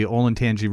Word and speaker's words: Olentangy: Olentangy? – Olentangy? Would he be crossing that river, the Olentangy River, on his way home? – I don't believe so.